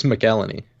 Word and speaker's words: McElhenney: How do you say Rob's last name? – I think it's McElhenney.